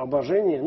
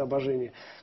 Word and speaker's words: обожение: Да, потому что именно поэтому здесь мы начинаем понимать, что обозначает слово «обожение». Ну, я произношу его со славянским ударением, как оно по-славянски говорится «обожение». Конечно, философы чаще говорят «обожение».